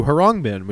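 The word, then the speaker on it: Khruangbin: And this next track is full credit to Harangbin, which is how you pronounce it.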